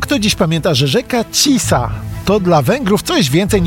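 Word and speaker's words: Tisza: Po węgiersku Tisa, nazwa pisana przez SZ, który wymawia się jako S.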